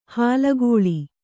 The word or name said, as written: ಹಾಲ್ಗಲ್ಲ